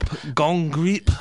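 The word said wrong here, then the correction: Gonggrijp: I think it's Rob Hongripe. – Hongripe. Okay, fair enough.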